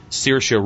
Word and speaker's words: Saoirse: The nominees for supporting actress are Kate Blanchett, I'm Not There, Ruby Dee, American Gangster, Saoirse Ronan, Atonement, Amy Ryan, Gone, Baby Gone, and Tilda Swinton for Michael Clayton. – Way right. to go with the Gaelic pronunciation there.